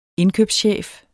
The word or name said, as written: indkøbschef